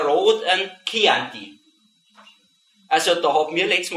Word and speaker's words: Chianti: Leute gibt es, kann ich euch sagen, die hat tatsächlich behauptet, das war so ein Chianti. Dabei ist das ganz klar, wenn also nach einem CAH kommt, dass das Chianti ausgesprochen wird. Chianti.